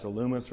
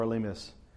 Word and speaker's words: Elymas: Now, just imagine, even if you're not Elymas, by the way, I think the Greek pronunciation of that is Illumis or something. I'm going to quit trying to pick those other pronunciations out. We'll just go with the normal English pronunciations.